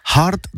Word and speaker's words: hart: Hard, alors ça s'écrit H-A-R-T avec un H aspiré.